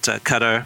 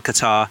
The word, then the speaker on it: Qatar: I think the thing that makes this very complicated is, depending on how you pronounce it, Qatar or Qatar, that bid really makes things difficult.